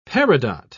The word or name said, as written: peridot